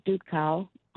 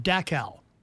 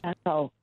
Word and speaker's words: Dachau: He was part of the unit that liberated Dachau. I'm not sure I'm pronouncing that right. – Dachau. But you're close. – Dachau.